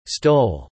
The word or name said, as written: stole